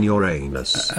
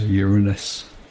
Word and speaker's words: Uranus: There are Klingons on Uranus. – Actually, I think the modern pronunciation for that is Uranus. – You know, everything is woke these days. So be it. Uranus, then.